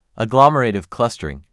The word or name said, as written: agglomerative clustering